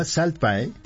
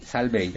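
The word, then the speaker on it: Salt Bae: Se llama Salt Bay. – Ajá. – O Salt Bay. – Salt Bay. – El de la sal, Salt Bay.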